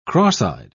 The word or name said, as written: cross-eyed